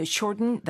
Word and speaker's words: shorten: 네, Can you shorten the hem to? 자, 방금 들으신 것처럼 shorten 이렇게 말하기보다는 short 하고 꿀꺽 삼키는 것 같은 발음으로 많이 발음합니다.